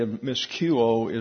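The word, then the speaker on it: Miscuo (μοιχεύω): Miscuo is the pronunciation of the Greek word there.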